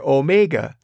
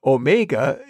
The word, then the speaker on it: Omega: The O in the biblical Greek letter Omega is commonly pronounced like the O in the English word tone, like what we would call a hard O pronunciation.